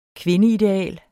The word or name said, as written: kvindeideal